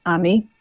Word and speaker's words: Aimee: Middle name is A-I-M-E-E. It's kind of spelled like Amy, but it's pronounced Ami.